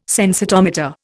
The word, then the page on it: sensitometer: Indian